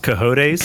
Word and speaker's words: cojones: It's pronounced cojones. Thank you. Spanish.